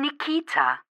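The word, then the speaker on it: Nikkita: I'm Nikita. – Can you spell that, please? – Sure. It's N-I-K-K-I-T-A.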